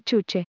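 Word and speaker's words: Juche: Pronunciada Chuche, en coreano, la palabra no tiene una traducción exacta en español, por lo que habitualmente se define como autosuficiencia o autoconfianza.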